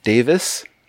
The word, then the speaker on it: Davies: They were friends with Ray and Dave Davis, as I've been told to pronounce it. – What? Oh, Dave, not Davies? – Not Davis? – Davies. It's Davis, apparently.